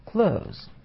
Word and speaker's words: clothes: Clothes часто произносят неправильно. В этом слове только один слог, и лучше всего и произносить как глагол clothes.